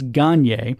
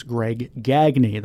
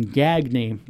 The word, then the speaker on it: Gagne: Now, my hockey mind says, no, it's Gagne, but it is actually pronounced, he prefers Greg Gagne. That's one of the things I remember from the 1991 World Series where the Twins beat the Braves, hearing Gagney, and all I could think of was like Valley Girl talk, Gagney with a spoon.